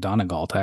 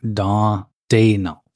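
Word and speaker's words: Daigneault: Donegalt, I always say, but it's not how it's spelled. Mark Daynault. That's how you say it, I think.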